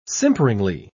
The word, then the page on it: simperingly: British English